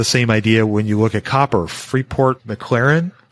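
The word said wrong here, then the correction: McMoRan: I think Macaron. – Yeah, thank you. I butchered that.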